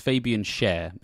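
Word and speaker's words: Fabian Schär: I'm trying not to butcher his name, but I believe it's pronounced Fabian Cher. It's like the singer. – Fabian Cher.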